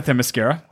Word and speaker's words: Themyscira: She says, you know, and she talks about Themyscira. That's how I'm pronouncing it. – That's correct. – Right, yeah.